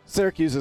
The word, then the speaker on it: Syracuse: By the way, I think you say Syracuse weird. I say Syracuse. You say Syracuse. – That's right. That's the right way to say it.